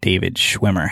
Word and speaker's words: David Schwimmer: Prime example, when I was at Chicago studying, David Schwimmer had opened up a theater outside in the suburbs of Illinois because he, did I say his name right from Friends? – Mm-hmm.